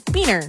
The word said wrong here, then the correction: Benner: Barend and Benner. – Barnard and Benner. – Barend and Benner. – Thank you.